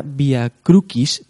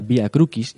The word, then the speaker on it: via crucis: La palabra via crucis, porque en latín eh, se, pronuncia via crucis y no via crucis, como algún latinajo italianizante quiere, alguno.